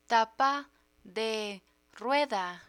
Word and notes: tapa de rueda: Spanish

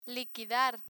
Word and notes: liquidar: Spanish

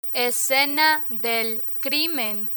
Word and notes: escena del crimen: Spanish

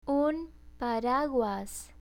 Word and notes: un paraguas: Spanish

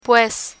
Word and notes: pues: Spanish

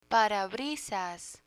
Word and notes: parabrisas: Spanish

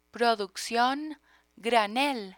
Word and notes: produccion granel: Spanish

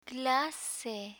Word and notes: clase: spanish

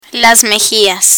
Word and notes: mejillas: Spanish

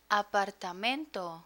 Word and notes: apartamento: spanish

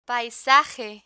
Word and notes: paisaje: Spanish

